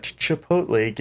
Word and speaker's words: Chipotle: Me and Jess were at Chipotle getting a lovely salad.